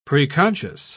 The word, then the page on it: preconscious: British English